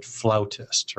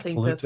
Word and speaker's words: flautist: She is I assume you pronounce it flautist, right? It's not flutist. – That's correct. – It would be flautist.